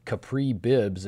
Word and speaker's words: Capri Bibbs: One of his backups, an interesting story to tell here, the Packers recently claiming Capri Bibbs, if I'm saying that correctly.